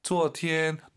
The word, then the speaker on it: 昨天: 이성 발음할 때 昨天 높이 시작해요. 낮게.